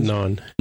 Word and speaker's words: Nhan: It's like spelled like naan, uh, the, the, Indian food. – N h a n, which is very close to how you say naan, which is the Indian bread.